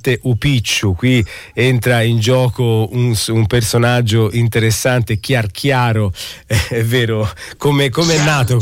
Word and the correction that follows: Chiarchiaro: Chiarchiaro, sì sì, io l'ho letto con l'accento sbagliato, ma è lui, Rosario Chiarchiaro. – Sì, è così che si pronuncia.